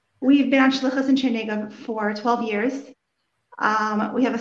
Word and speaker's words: Chernigov: Is Shlucha to, and I'm going to mispronounce it, Chernigov, Ukraine? – Chernigov, Ukraine.